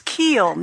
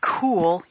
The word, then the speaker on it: Kiel: It's Kiel, not cool, you podcasting fool. – Yeah, that's it. And so she's like in Kiel, in her, as in Neil.